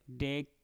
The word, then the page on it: dek: Mpur